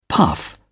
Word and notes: puff: British English

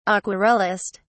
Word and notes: aquarellist: British English

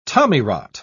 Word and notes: tommyrot: British English

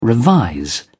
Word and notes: revise: British English

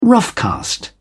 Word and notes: roughcast: British English

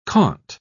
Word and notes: Comte: British English

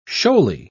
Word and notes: shoaly: British English

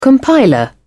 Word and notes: compiler: British English

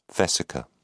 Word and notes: vesica: British English

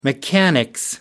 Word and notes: mechanics: British English

